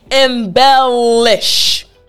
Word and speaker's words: embellish: So again, the key is making sure the tip of your tongue touches the roof of your mouth. So this word, again, embellish. Yes, again after me, embellish. Excellent. So for this word, to pronounce it properly, once you have that L sound down, you will actually pronounce it like a native English speaker.